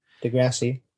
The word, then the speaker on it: deGrasse: Neil, De, wow, oh, Neil deGrasse Tyson, is that how you say his name? – Yeah, deGrasse.